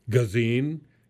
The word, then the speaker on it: Gesine: And more importantly, we'll finish up a chat with Gesine, rhymes with subpoena, says right on her site.